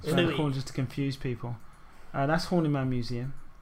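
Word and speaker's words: Horniman: So you've got God's Own Junkyard left, the National Gallery, I think, and the Horny Man Museum. Am I saying it right? Horny Man.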